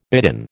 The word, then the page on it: bidden: American English